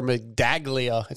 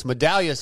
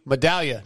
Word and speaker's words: Medaglia: He always says Tyler Medaglia. It's Medallia, Steve. Medallia. No G. The G is silent.